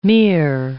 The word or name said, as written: Mir